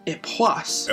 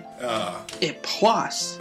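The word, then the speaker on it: Époisses: So repeat after me. Epoisse. – Epoisse. – Try it again. Epoisse. – Epoisse. – Very good. – I'm going to shut up now. – Epoisse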